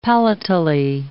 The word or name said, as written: palatally